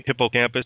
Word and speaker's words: hippocampus: And, of course, you mentioned the hippocampus. Did I say it right? – Hippocampus, yeah. – Hippocampus. So the O is kind of like not emphasized.